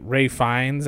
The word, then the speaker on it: Ralph Fiennes: Every scene with Ray, it's like Ray Fiennes, I think is how you say his name. Ralph Fiennes is what I always want to say. Is it, what is it? – What?